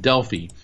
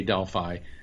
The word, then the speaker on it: Delphi: In the ancient Greco-Roman world, the Oracle of Delphi, My Greek friends keep correcting me and telling me it's pronounced Delphi, but I say Delphi, was sacred to the god Apollo.